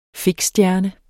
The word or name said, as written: fiksstjerne